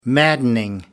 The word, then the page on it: maddening: American English